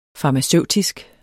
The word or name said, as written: farmaceutisk